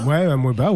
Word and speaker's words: Amoeba: A-M-O-E-B-A. Amoeba ? – Ouais, Amoeba, ouais.